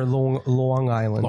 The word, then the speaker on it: Long Island: So is it Long Island or Long Island? – Long Island. – Long Island. – It's the hard G.